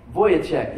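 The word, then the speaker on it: Vujicic: But anyways, in the middle of that, they read from the Message translation of 1 Corinthians 13, and the guy, Vizicic, how do you say it? Vizicic, the guy with no arms and legs? Say it loud. Voyacek, yes, that guy.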